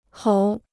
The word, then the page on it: 猴: hóu